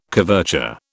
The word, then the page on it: coverture: British English